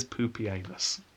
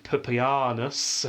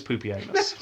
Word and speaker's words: Pupienus: Some people try and get around this by pronouncing it Pupianus, a really stress of the parts, but no. And I even did some research to make sure I was pronouncing it correctly. His name is Pupienus.